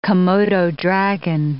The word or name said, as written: Komodo dragon